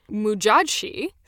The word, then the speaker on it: Modjadji: Mujadshi, so I'm so glad I could find a pronunciation for this one online because a lot of the other names I couldn't, but it's spelled Modjadji.